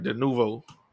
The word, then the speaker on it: Denuvo: I think I said it right. Denuvo. Yeah, Denuvo.